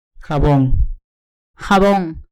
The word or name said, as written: jabon